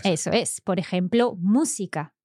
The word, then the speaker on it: música: So música, a third last syllable pronounced. Okay, so música, música, música. – Mm-hmm. – And that's an esdrújula. – Yes. – And there's an accent on the mu.